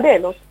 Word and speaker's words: Dellert: Det var bara uttalet på efternamnet som var lite fel, men hon heter Kerstin Dellert. – Ja, jag sa Dellert.